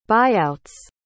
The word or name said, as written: buyouts